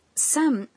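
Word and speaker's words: San (山): No, aunque se pronuncia igual. Montaña se dice... – Yama. – Pero se pronuncia... – San. – Cuando se coloca detrás del nombre de algunas montañas.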